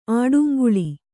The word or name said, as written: ಆಡುಂಗುಳಿ